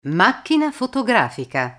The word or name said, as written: macchina fotografica